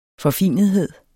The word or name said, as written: forfinethed